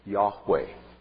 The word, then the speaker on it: Yahweh: And it's usually pronounced Yahweh.